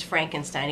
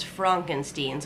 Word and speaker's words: Frankenstein: Well, Vern Miller plays Frederick Frankenstein, and he doesn't even want people to pronounce his name as Frankenstein. He wants people to pronounce it as Frankenstein, so he's not even associated with that family.